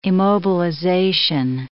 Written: immobilization

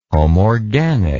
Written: homorganic

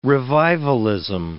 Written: revivalism

